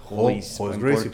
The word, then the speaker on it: Gracie: Royce, Gracie, porque, en portugués la R al principio se pronuncia como H.